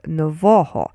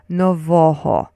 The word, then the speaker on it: Нового: So so say Novoho, but only with the stress on the middle syllable, which is Novoho. So say it with me, Novoho.